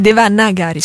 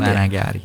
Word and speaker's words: Devanagari: in Devaganari. Che è una delle tante Devaganari. – La lingua dei testi sacri. – Anzi, Devanagari, scusatemi, faccio sbagliare. – Devanagari.